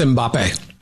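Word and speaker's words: Mbappé: That's Mbappe.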